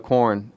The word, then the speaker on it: Quorn: First of all, how do you pronounce it? Because every time I say corn, I think of... – It was same. – The band. – Corn. – It's spelled Q-U-O-R-N, right? – Yeah.